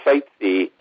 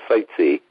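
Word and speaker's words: Sitzai: His name is Bill Seitze, and it's like if you go sightseeing, that's how you pronounce it, but it's spelled pretty strange. It's spelled S-I-T-Z-A-I, S-I-T-Z-A-I, but it's pronounced Seitze.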